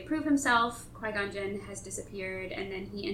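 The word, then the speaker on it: Qui-Gon Jinn: So it's when he's under Qui Gon Jinn. That's how you say it, right? – Yes.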